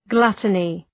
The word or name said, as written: gluttony